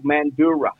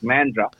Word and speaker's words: Mandurah: And I'm going to live in a place called Mandurah, which is pronounced Mandra. But right, with a South African accent, it was Mandurah.